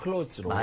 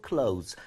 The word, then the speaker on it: clothes: 무조건 clothes로. – 맞아요, 맞아요. 네, 그게 어떻게 보면 천이 막 붙어가지고 옷을 형성하는 거니까 그렇게 약간 복수로 clothes. 아, 그냥 C-L-O-Z-E 정도 되는구나라고 생각하시면 될 것 같습니다. 발음 자체가요.